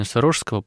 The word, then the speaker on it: Сурожского: Ну вот вы упомянули владыку Антония Сурожского, или как правильно вы ставите ударение, Сурожского, потому что город Сурож, по имени которого епархия названа, и титул дан был митрополиту, хотя как-то в народном сознании уже закрепилось неправильное ударение, и я знаю, что люди, вот, духовные чада, владыки, они так смиренно это принимают и уже даже не поправляют иногда.